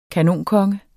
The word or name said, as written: kanonkonge